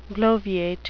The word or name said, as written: bloviate